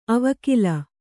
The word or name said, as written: ಆವೆಕಲ್ಲು